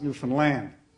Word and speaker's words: Newfoundland: You know, I just came back recently from gospel meetings in, as they pronounce it, Newfoundland. You've got to put the emphasis on the last syllable there.